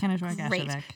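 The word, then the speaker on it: Kenojuak Ashevak: So a little while ago we did an episode on, and I might butcher this, Kenojuak Ashevek. – Perfect. Kenojuak Ashevek.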